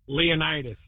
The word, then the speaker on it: Leonidas: Is it Leandis? – Leonidas. – Leonidas.